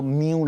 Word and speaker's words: mu: 听说这个应该要念作 mu 了哈，就是阿尔法啦、贝塔啦、伽马啦，一直轮轮轮轮到 m 啦，轮到 mu 啦。